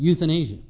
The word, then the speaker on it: euthanasia: And the taking of life at the end of life is called euthanasia. Not youth in Asia, Y-O-U-T-H. But that is how it's pronounced, euthanasia.